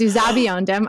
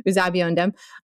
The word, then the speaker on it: Uzuegbunam: It's Uzabiondem. I listened to Chief Justice's pronunciation several times. Uzabiondem was looking for that here too.